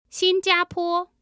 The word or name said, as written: xinjiapo